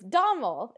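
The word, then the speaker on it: Domhnall: And it's Domel is how you say it. And that's the guy's name is Domel. But it's spelled with an N because Gaelic is so freaking hard to learn the spelling rules.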